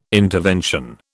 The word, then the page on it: intervention: British English